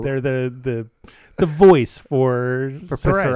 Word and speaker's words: Psoriasis: So the best tagline I've heard of a company recently, I'm working on a website for the National Psoriasis Foundation. Okay. To which their tagline is, the P is silent, but we are not.